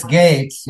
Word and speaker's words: Gaetz: How do you pronounce it? Gaetz. I'm not even sure how to pronounce the word. – Yeah, I've heard it's Gates, yeah.